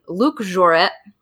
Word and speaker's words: Luc Jouret: The founders were this guy named Luc Jouret.